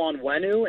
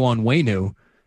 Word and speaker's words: Onwenu: If you want to learn more about Michael Onwenu, talk to his offensive line coach. – Is that the proper pronunciation, Onwenu? – That's how I understand it.